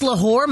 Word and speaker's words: Lahore: Can you imagine what Miss Lahore might have wrote up on that board? – Yeah. Am I saying her name right? Jessica? – Ooh, yep. You love Lahore. – L apostrophe whore. – W-H-O-R.